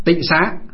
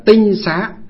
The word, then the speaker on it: tịnh xá: Tịnh xá là chỉ cho là nó gọi, bây giờ ngày xưa nguyên âm của nó gọi là tinh xá, nhưng bây giờ chúng ta đọc là tịnh xá.